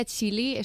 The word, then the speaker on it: Qili: Bueno, antes que nada, para situar a los oyentes, ¿qué es la Fundación Kili? – Eh, bueno, eh, corrección pequeñita, se pronuncia Chili,